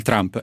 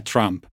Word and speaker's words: Trump: Ovviamente la pronuncia corretta del cognome del presidente e di sua nipote è Trump, ma in italiano ormai ci siamo molto abituati a usare Trump ed è quello che faremo in questa versione doppiata.